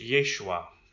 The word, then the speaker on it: Yeshua: That name in Hebrew is pronounced Yeshua, which can also be understood to be Joshua.